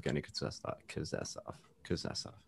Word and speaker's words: Evgeny Kuznetsov: player on the surface of Evgeny Kuznetsov.